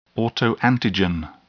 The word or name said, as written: autoantigen